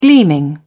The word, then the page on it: gleaming: British English